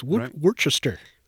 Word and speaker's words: Worcester: Worcester is, it's not spelled like Ohio's Worcester. – No, so it's Worcester.